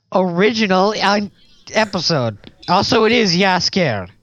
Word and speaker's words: Jaskier: Yaskier is spelled J-A-S-K-I-E-R. – Yeah, it's Yaskier.